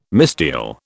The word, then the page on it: misdial: British English